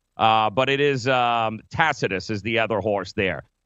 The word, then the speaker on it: Tacitus: B-O-D-E. Bodie Express, High Call, and it's Tacitus. The C is actually pronounced like an S, not a C. It's not Tacitus. It's actually Tacitus with an S.